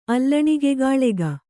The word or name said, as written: ಅಲ್ಲಣಿಗೆಗಾಳೆಗ